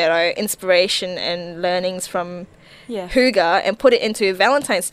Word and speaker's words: hygge: Hygge, H-Y-G-G-E. I don't know why it's pronounced that, but Hygge sort of says, you know, just live comfortably, be comfortable, make everything warm and comfy.